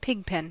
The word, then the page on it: pigpen: American English